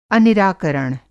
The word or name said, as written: अनिराकरण